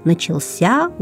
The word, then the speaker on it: начался: Это слово, прошу заметить, произносится с ударением на последнем слоге. Итак, начался учебный год.